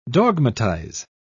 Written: dogmatize

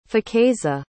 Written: faqueza

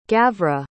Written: gavra

